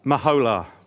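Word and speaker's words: Meholah: How would you say that? Abel Maloa? See, I'm not that good on Hebrew. What's that? Mahola. Well played.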